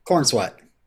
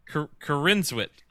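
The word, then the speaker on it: Corenswet: When he got cast, I specifically did research to figure out on how to pronounce the name. – Corn is wet. – Corinswet. I think it's Corinswet. It's not corn sweat, Robin. I know that much.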